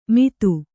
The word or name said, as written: ಮಿತ್ತು